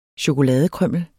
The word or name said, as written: CHOKOLADE